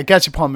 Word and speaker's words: Gachapon: I know that in Japan there's a big culture with the I might be mispronouncing it, but is it Gachapon? – Yeah, Gachapon machines.